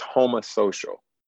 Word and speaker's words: homosocial: It's homosocial, like homo. – Oh, homo. No, I thought but it is spelled H-O-M-O. – Oh, so but the O is saying homosocial.